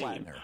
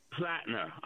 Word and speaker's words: Platner: All this talk about Glenn Platner, I might mispronounce his last name. – Platner. – Platner. I'm so sorry.